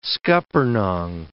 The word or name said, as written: scuppernong